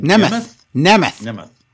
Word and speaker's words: Nemeth: Nemeth.